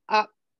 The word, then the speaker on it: Optavia: So the thing that confuses me about Optavia, yes. – Did I say it right? – You did.